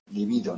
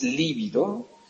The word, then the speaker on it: libido: Fijaros además cómo se escribe y pronuncia libido. No es líbido, y con V, que significaría quedarse pálido, ¿no?, sino libido, pronunciado de esta manera, que es el concepto que Freud emplea para referirse a la disposición erótico-sexual, ¿no?, que encontramos en todos nosotros, ¿no?